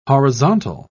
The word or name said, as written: horizontal